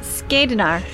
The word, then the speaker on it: Skadnar: you're facing the, how did you pronounce it? – Skadnir or something. – Skadnar. – Skadnar. – Skadnar. – You're facing, and we're going to go with Skadnar because it actually just sounds cooler. Cool.